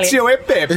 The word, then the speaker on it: cacio e pepe: Cacio e pepe.